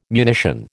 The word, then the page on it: munition: American English